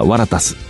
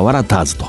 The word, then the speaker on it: ワラターズ: ワラタス、えー、最近ではワラターズと、まあ、現地に近い発音でこう呼ぶ